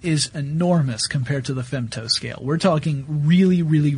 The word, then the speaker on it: femto: And this is on the actually the femto scale. – Yeah, am I saying that right? – That's correct. Yeah.